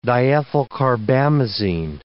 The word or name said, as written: diethylcarbamazine